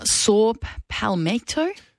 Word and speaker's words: saw palmetto: On the efficacy of saw palmetto? – Saw palmetto is...